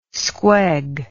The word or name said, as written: squeg